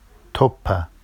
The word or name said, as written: toppa